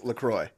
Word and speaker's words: LaCroix: Except the company gets to self-identify and they say it's LaCroix. – Oh, really? – On the website. They say pronounce it as in enjoy.